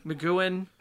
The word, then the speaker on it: McGuane: Like, I'm a big Thomas McGuane. Is that how you say it? – Yeah. – McGuane.